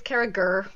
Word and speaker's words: Carriger: But it's Kara Gurr with the hard G.